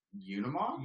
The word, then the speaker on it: Unimog: This was the Bilstein Unimog.